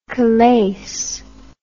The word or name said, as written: callais